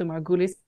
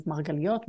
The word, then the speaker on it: Margulis: So Margulis is the Ashkenazi pronunciation of Margaliot, Margulis.